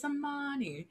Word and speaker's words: Imani: says my name the way it's supposed to be pronounced, because I tell everybody, oh, my name's Imani, because I work in corporate America. So it's all full of white people. I'm like, oh, it's Imani.